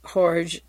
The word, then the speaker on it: Jorge: Colonel Park attempted to establish a beachhead in Argentina under the dictator presidency of General Jorge George Videla, J-O-R-G-E, the J is silent, Jorge Videla.